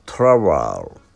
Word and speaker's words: trowel: Travel、 T-R-O-W-E-L、これが意味はですね、コテです。